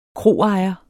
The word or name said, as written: kroejer